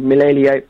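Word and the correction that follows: Milivojević: I don't even know how to pronounce his name. – Milivojevic. – Yeah.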